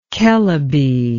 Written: Kelebe

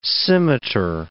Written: scimitar

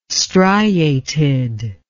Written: striated

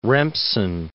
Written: Remsen

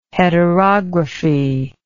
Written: heterography

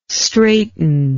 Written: straiten

